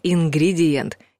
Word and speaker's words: ингредиент: Вот только беда в том, что даже на популярных кулинарных сайтах встречается написание «ингредиент» или даже «индигриент». Там же живут креветки. Сложность возникла из-за того, что ударение падает на четвертый слог — «ингредиент», а все предыдущие гласные остаются не у дел.